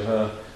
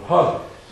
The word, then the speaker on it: Hur: It says also that Hur, I'm trying to pronounce it, I normally say her, but I'm trying to give you the R's that you have in Scotland. Hur.